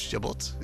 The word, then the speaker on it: Chabot: Zeg ik het goed? Chabot? Ja, Chabot, zo staat het er.